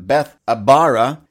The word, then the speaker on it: Bethabara: So the use of that word, Bethany or Betharba, how do I say that? Bethabara, your Bethany, your misery, your place of misery or depression becomes your place of crossing over into something better.